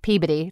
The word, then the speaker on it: Peabody: Peabody. It's called. It's pronounced Peabody. – Peabody. – Peabody. – And when okay. If you're from Massachusetts, we say Peabody.